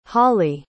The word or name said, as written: hawley